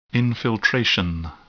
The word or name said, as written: infiltration